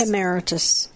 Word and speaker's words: Emeritus: Emeritus. – Emeritus.